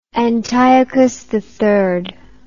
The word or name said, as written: Antiochus III